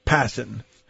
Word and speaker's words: Passan: Before we get to the author, Jeff Passan, I always pronounce his name wrong. We'll get to that in a second. Passan.